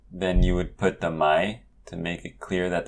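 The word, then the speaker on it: mai (ไหม): The word is written as mai, rising tone, but when you pronounce it, it's always mai, so it will be a high tone.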